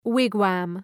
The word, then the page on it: wigwam: ‘wıgwɒm